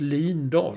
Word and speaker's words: Lindahl: Hon gick ut och sa det, från och med nu ska det uttalas Lindahl.